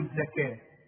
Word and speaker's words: الزكاة: وآتوا الزكاة، الزكاة بتكتب بالواو دائما في القرآن وتنطق بالالف، نحول الواو الى ايه؟ الف. الزكاة خلاص.